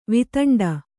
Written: ವೇತಂಡ

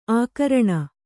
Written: ಆಕರಣೆ

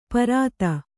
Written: ಪಾರುಧಿ

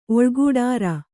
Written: ಒಳ್ಗೂಡಾರ

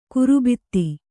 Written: ಗರ್ಬಿತ

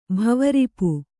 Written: ಭವರಿಪು

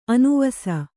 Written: ಅನಿವಾಸಿ

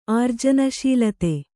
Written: ಆರ್ಜನಶೀಲತೆ

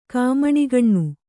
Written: ಕಾಮಣಿಗಣ್ಣು

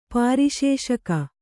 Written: ಪರಿಶುಷ್ಕ

